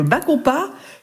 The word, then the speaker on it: bacopa: Et ce qui serait bien d'associer, c'est ce que j'ai dit tout à l'heure, c'est une plante qui s'appelle le bacopa, qui est très utilisée en Asie, en médecine ayurvédique, pour favoriser la concentration et la mémoire.